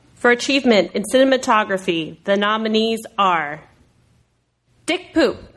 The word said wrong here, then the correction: Pope: Dick Pope, or Mr. Turner.